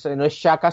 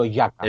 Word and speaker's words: Xhaka: Me dijo que el problema que teníamos es que no pronunciábamos bien, porque no es Xhaka, sino Xhaka.